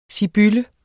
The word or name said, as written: SIBYLLERNE